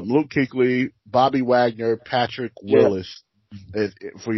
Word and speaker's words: Kuechly: That's how you say it, K-U-E? Oh, that's pronounced Kuechly.